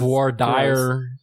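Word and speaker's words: voir dire: I was just making a joke about how I didn't, because it was Vore Dyer. We pronounce it Vore Dyer in Texas.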